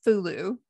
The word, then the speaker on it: Hulu: So for example, with company names, like in English, the streaming service Hulu, Hulu, the H-U, Hulu. But in Japanese, it's pronounced Fulu. – Fulu. – Yeah, Fulu. With a F-U sound.